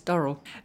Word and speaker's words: Durrell: Oh, by the way, I pronounce it Durrell. Should we have an adjudication? I notice you're pronouncing it Durrell, Sam. Should we have an adjudication from one who knows? – She who knows says it's Durrell.